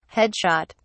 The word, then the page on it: headshot: British English